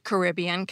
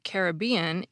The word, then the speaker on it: Caribbean: Dictionaries list both pronunciations as acceptable, and there are actually even more than those two. A 2010 book on pronunciation says that Caribbean is more common among British speakers, and Caribbean is more common among American speakers.